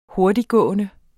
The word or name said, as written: hurtigtgående